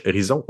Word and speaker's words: Ohrizon: Puis Horizon, ça s'écrit O O-H, Horizon, Horizon, parce qu'ils font dans le AR.